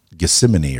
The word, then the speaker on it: Gethsemane: In the Garden of Gethsemane or something.